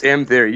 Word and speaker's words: M-Theory: Is that how you say it? Because I always say in my head, like, M. It's M-Theory. You guys got it. You nailed it.